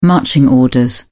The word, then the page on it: marching orders: British English